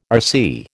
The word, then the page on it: RC: American English